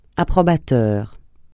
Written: approbateur

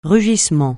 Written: rugissement

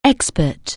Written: expert

